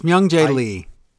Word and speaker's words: Myung Jae Lee: It's Myung Jae Lee. – Hung? How do you say that? – Myung Jae Lee. – Like the philosopher? Hume? Maybe. Jung? Jang? – Hung? No, it's Myung with an M.